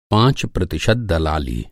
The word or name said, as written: दलाली